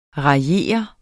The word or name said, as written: raillere